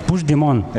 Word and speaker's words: Puigdemont: Puigdemont. – Eso es. – Sí, lo dices como francés, así. Tiene Puigdemont, como parece como una isla.